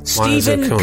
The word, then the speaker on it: Stephen: Stephen Petrie. – Stephen Petrie. How do you spell that? – Like it sounds. Stephen is with the PH.